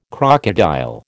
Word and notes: crocodile: American English

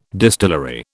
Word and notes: distillery: British English

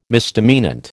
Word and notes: misdemeanant: American English

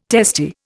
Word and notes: testy: Indian

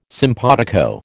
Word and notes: simpatico: American English

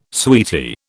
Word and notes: sweetie: British English

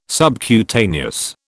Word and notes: subcutaneous: British English

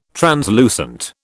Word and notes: translucent: British English

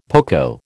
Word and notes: poco: American English

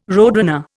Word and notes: roadrunner: Indian

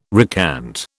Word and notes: recant: British English

